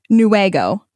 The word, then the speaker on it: Newaygo: It's not Newaugo, wag like a dog's tail. – So pronounce this for me. – Newaygo.